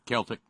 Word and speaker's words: Celtic: Is it Celtic or Celtic? – Celtic with a K.